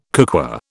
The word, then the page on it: cookware: British English